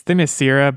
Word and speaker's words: Themyscira: So from what little was revealed about this Wonder Woman game is that you're basically going to be the bridge between Themyscira. Is that how I'm pronouncing that right? I am, right? – I think it's Themyscira, but I don't know. Themyscira, you're fine. You're doing great.